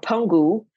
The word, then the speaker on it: Penghu: There's also, if you're going for like a weekend trip, there's an island called Penghu or Penghu, is how they pronounce it.